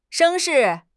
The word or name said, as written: 声势